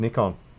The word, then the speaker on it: Nikon: It's Nikon. – Really? – Yeah, that's how we say it in Australia. – Oh, interesting. – It's a short I versus the long I.